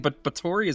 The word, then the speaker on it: Bathory: You keep saying Bathory, is that correct? – It is the correct pronunciation.